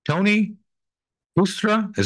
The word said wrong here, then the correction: Cuesta: is that how you pronounce it? – Tony Cuesta. Tony Cuesta.